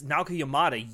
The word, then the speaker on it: Naoko Yamada: You need an anime version of When Harry Met Sally directed by Naoko Yamada? Right? Right? – I didn't butcher that? Yes. Yes. Naoko Yamada, yes.